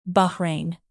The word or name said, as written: Bahrain